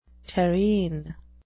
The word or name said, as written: terrene